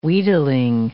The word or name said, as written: wheedling